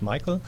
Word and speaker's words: Michael: I remember that part, but you said at home in Germany you pronounce it another way, right? – That's true. That's true. Okay. Michael is fine.